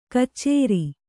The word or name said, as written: ಕಚ್ಚೇರಿ